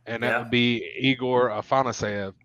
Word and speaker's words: Afanasyev: First of all, you say his name so beautifully, Afanasyev.